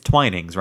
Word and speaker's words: Twinings: It's Twinings, right? Not Twinnings.